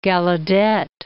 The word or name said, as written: Gallaudet